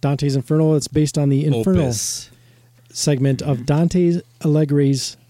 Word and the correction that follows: Alighieri: How do you say that? Allegri's. – I think it's Allegri. – Dante Alighieri's epic poem, The Divine Comedy.